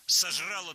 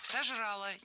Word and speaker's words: сожрала: Сожрала два куска торта и ну, что-нибудь такое. – Сожрала. Неверно. Правильно, сожрала. Ударение опять же переносим в конец слова.